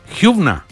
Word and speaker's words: Hübner: La pronunciación de Hübner es un apellido alemán. Si somos rigurosos, se pronunciaría Hübner. Hübner. Ah, puede ser. Puede ser. Pero chilenizado sería Hübner, claro. Pero se pronunciaría Hübner. Hübner. Hübner. Y con acento alemán.